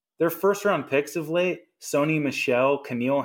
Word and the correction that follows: N'Keal: Henry, however you pronounce that guy's name. – N'Keal. They're N'Keal, their their fucking offense is terrible.